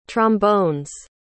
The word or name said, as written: trombone's